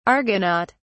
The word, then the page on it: argonaut: British English